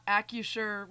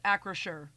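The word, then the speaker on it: Acrisure: It is not AccuSure with a silent R. It is AccraSure with an R.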